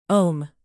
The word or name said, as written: ohm